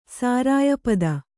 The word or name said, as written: ಸಾರಾಯಪದ